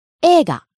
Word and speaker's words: Eiga: E-I-G-A. Eiga. – Two pronunciations. Naomi-sensei gave us rising intonation. – Hai. – Rising on the second syllable E and staying high. – But another pronunciation is. Ega. – Falling on the second syllable E and staying low.